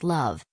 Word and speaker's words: Loev: The featured article for Thursday, June 2, 2022 is Loev. Loev, pronounced Love, is a 2015 Indian romantic drama film written and directed by Sudhanshu Saria.